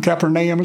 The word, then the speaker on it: Capernaum: Remember when he said, when Jesus really was fed up with Chorazin, Bethesda, and, you know, Capernaum, Capernaum is how they actually pronounce it.